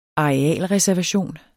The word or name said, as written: arealreservation